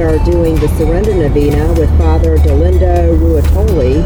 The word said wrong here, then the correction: Ruotolo: I didn't say that right, his last name, but it's precious to me. – I think it's Ruotolo, is that maybe.